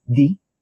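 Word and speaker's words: Dy: My last name is actually pronounced as D, not Dai.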